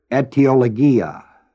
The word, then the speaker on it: etiologia: The figure in the Greek is the figure etiologia. It's spelled A-E. T-I-O-L-O-G-I-A, etiologia. It comes from the word aitia, A-I-T-I-A, and the word logos, L-O-G-O-S. The word A-I-T-I-A, aitia, means a cause or a reason.